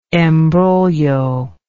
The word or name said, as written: embroglio